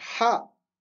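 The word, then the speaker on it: Ha (ح): Ensuite, Ha. Donc c'est pareil que le Jim, sauf qu'il n'y a pas de point. Ha. Et pour prononcer le Ha, il faut que l'air sorte de la gorge.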